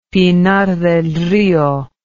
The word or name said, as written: pinar del rio